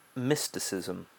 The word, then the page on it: mysticism: British English